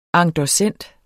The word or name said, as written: endossent